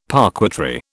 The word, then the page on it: parquetry: British English